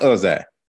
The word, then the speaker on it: Uzziah: It's pronounced Uzziah, who he was.